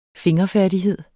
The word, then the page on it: fingerfærdighed: [ -fæɐ̯ˀdiˌheðˀ ]; [ -fæɐ̯diˌheðˀ ]